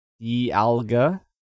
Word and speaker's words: Dialga: I think I'm finally saying it right. Because that's how the Pokedex 3DS app says it. Dialga.